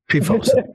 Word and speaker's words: PFOS: PFOS. – That's how NPR pronounces it. PFOS.